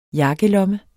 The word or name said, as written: jakkelomme